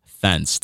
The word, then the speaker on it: Thens: So yeah, my government name is Thence Duviela. My first name is Thence. You hear that right? Thence, T-H-E-N-S.